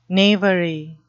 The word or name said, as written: knavery